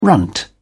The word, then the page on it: runt: British English